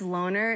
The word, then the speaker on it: Lonr: And they broke, his name is Loner. It's spelled L-O-N-R.